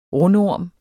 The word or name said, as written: rundorm